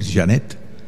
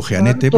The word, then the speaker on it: Jeanette: Claro, yo no tengo por qué saber francés y pues no tengo por qué decir Janet. – Ah, claro que no. – Yo digo Janete porque se escribe Janete.